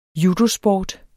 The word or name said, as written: judosport